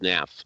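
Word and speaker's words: Hnath: I assume it's just Nath. I assume the H is silent. Hanath, anybody? Uh, I, have heard it Hanath.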